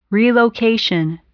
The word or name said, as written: relocation